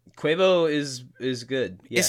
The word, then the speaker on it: Quavo: Is that how you say it? Quavo? – Yeah, Quavo.